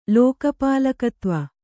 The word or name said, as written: ಲೋಕಪಾಲಕತ್ವ